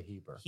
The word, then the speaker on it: Heber: Holy, holy, holy Lord God Almighty by Reginald Haber. Heber? Heber. I would say Heber.